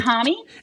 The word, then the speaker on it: Homme: Josh Homme, like Homme Mommy. – Oh, it's Homme. It's pronounced Homme. – It's Josh Homme.